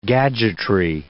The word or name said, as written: gadgetry